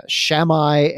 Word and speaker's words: Shammai: There was Shammai and Hillel.